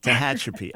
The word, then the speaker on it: Tehachapi: Tehachapi. – All right, I had the accent in the wrong place. Now I got it. Tehachapi. Accent on the second syllable.